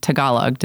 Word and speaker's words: Tagalog: You sing in both English and Tagalog. Did I say that correctly? – Yes, that's right. Very, very well.